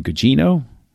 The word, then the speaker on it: Gugino: I think Carla, and I always butcher her last name, Gugino? – Mm-hmm. – Yeah, that's how you pronounce her last name.